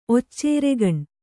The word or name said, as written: ಒಚ್ಚೇರೆಗಣ್